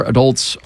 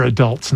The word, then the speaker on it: adults: There's a new CDC report saying that 3 out of 4 adults are feeling fatigued. – First of all, they're adults, not adults.